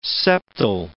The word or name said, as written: septal